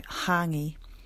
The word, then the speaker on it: hangi: So the accent over the vowels makes the sound longer. And the NG in Māori is pronounced just like the NG that we would say in singer. So hangi.